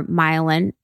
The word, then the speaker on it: myelin: And when we talk about the brain development, you know, when we talk about a time when there is more myelin, is that how do you say it? – Myelin. – Yeah. Myelin. Okay, cool.